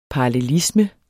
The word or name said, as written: parallelisme